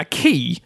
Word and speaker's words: Quay: A key, spelt Q-U-A-Y, is a stone or metal platform lying alongside or projecting into water for loading and unloading ships. Quay is also spelt Q-U-A-Y, but pronounced Quay.